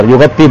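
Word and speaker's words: yaghatti (يغطي): Saya tulisnya apa? menutupi, yaghatta, yaghatti, benar, yaghatti bacanya. Ya, menutupi.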